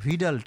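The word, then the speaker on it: Widal: UIDAL কিন্তু উচ্চারণটা ভিডাল test.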